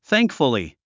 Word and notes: thankfully: θǽŋkf ə li; サンクフリィ

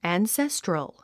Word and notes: ancestral: ænséstrəl; アンセストゥラル

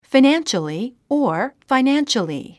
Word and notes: financially: finǽnʃəli; ファイナンシャリィ

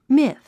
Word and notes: myth: míθ; ミィス

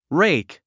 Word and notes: rake: réik; レェイク

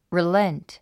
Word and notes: relent: rilént; リレント

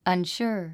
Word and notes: unsure: ʌnʃúə r; アンシュア

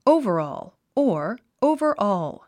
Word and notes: overall: óuvərɔ̀ːl; オゥバァオーゥ

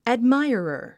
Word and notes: admirer: ædmái ə rə r; アドマイアラァ

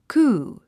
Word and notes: coup: kúː; クー

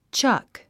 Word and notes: chuck: tʃʌ́k; チャァック

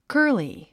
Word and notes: curly: kə́ː r li; カァリィ

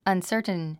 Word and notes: uncertain: ʌnsə́ː r tn; アンサートゥン

